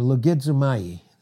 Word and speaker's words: logizomai: The Greek word is logizomai, or logizomai, proper pronunciation.